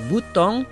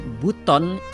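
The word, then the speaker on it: Buton: Chers amis, de la capitale de Bouton, c'est la prononciation de l'Indonésie, c'est Bouton. Mais pour la français, peut-être, on appelle Bouton.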